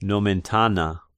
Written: Nomentana

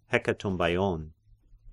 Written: Hekatombaion

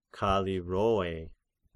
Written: Kallirrhoe

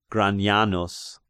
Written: Granianus

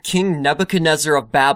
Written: Nebuchadnezzar of Babel

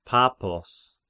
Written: Pappos

